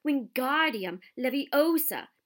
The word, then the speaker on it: Wingardium Leviosa: "Ingardium Leviosa," he shouted, waving his long arms like a windmill. "You're saying it wrong," Harry heard Hermione snap. "It's Wingardium Leviosa. Make the gar nice and long."